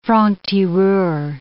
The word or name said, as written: franc-tireur